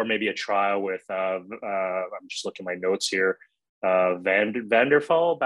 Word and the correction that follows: Vattenfall: battery power as a service, Vattenfall. Yes. I'm probably pronouncing Vattenfall, exact Vattenfall.